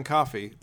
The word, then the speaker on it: Coffey: Oh, John Coffey. Like the drink, but not spelled the same.